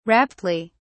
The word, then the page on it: raptly: British English